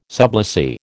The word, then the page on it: sublessee: American English